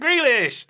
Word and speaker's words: Grealish: It's Mr. Grealish.